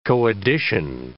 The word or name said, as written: co-edition